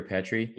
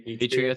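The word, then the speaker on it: Petry: And Petri, is that how you pronounce his name from Montreal? Petri or Petri? – Petri, I think. Yeah, like, Petri.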